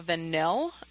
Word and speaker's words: Vanille: The deodorant, while you're looking for your mascara, that I use is called La Vanille. It's spelled vanilla, but it's pronounced vanille.